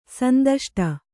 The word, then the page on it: ಸಂದಷ್ಟ: sandaṣṭa